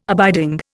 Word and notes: abiding: Indian

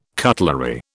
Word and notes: cutlery: British English

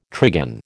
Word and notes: trigon: American English

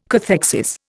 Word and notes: cathexis: Indian

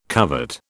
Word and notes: covert: British English

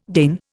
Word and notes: Dane: Indian

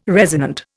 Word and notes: resonant: Indian

